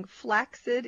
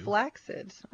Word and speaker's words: flaccid: But they also I used it wrong. Flaccid is what they say is the way to pronounce it. – Well, I didn't want to make a fool of you. – Flaccid.